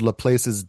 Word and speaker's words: Laplace: It's called Laplace's Demon. – I think it's pronounced Laplace.